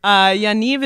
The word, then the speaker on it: Yaniv: Yaniv is pronounced like Yaniv, and it rhymes with achieve.